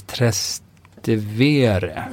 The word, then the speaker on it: Trastevere: och det uttalas Trastevere.